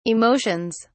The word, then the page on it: emotions: British English